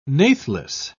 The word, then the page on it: natheless: British English